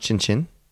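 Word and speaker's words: tchin-tchin: Is that pronounced chin chin? – It's pronounced like chin chin.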